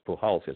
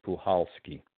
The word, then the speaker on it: Puchalski: You know, and the consensus was, and you know, some very knowledgeable people said it's the C is silent, so it's Pulhowski. It's not, because I was pronouncing it Pukalski to Pulhowski. I wasn't sure, but the C is silent, and the correct pronunciation is Pulhowski.